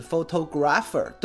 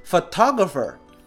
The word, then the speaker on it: photographer: 很多同学啊啊都知道，但是呢可能会念错，念成是 photographer，对吧？哎，这是不对的。这个词儿呢重音在后面，正确的念法是 photographer。